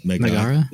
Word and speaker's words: Mehrgarh: Mehrgarh? Magar? Mehrgarh. – Megara is a fair pronunciation.